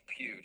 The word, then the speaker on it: Pugh: Yeah, it's pronounced Pew, just like the church pew.